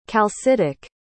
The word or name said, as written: calcitic